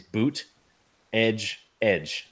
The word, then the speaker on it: Buttigieg: He put in parentheses boot, edge, edge. So I don't know if it's Buttigieg or Buttigieg or Buttigieg, but you know, it's boot, edge, edge. Those are the three, you know, syllables or whatever.